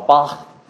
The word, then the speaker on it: Bach: In German, we say Bach, but they actually call Bach. That's the proper way. If they would correct you on that if you were in Germany and Europe, I'm sure, to make sure that you pronounce his name right.